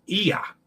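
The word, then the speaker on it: Oia: And the village on Santorini is Ia. Ia. Okay, because that must be an O-I is pronounced E.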